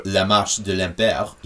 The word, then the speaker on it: La Marche de l'Empereur: March of the Penguins, or La Marche de l'Empereur in the French pronunciation, is a 2005 French feature-length nature documentary directed and co-written by Luc Jacquet.